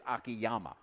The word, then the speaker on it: Akiyama: The American pronunciation is Akiyama.